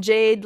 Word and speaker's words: Jade: My name is spelled kind of funny, but if you can figure it out, it is Kaeley, and then Jade, like the cool green rock.